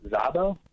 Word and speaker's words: Szabo: and that's Inezka S-Z-A-B-O. How do you say her name? – Inezka Zabo? Zabo? Yeah.